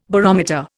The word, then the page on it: barometer: Indian